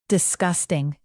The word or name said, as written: disgusting